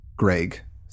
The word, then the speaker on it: Greig: His name is spelled G-R-E-I-G, Greg. So that might trip things up.